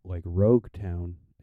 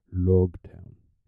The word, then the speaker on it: Loguetown: So when they say Rogue Town, it sounds like Logtown. It kind of has that L in it as well. And so with that, it could be either pronounced Rogue Town or Logtown.